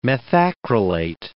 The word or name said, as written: methacrylate